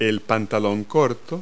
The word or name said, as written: shorts